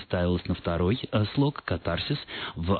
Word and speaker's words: катарсис: В традициях русской культуры, насколько я могу представлять себе, как минимум два века, когда, собственно, стали появляться первые орфологические словари, словари трудностей, в слове «катарсис» ударение должно ставиться на первый слог, и большинство орфоэпических словарей именно только как один единственный вариант и фиксируют вот эту вот форму.